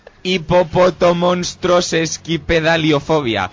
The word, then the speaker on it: hipopotomonstrosesquipedaliofobia: Hipopotomonstrosesquipedaliofobia. – Señor Cine, repita. – Espérate. – Señor Cine, repita. – Hipopotomonstroesquipedaliofobia. – No, es sesquipedaliofobia, no esquipedalifobia.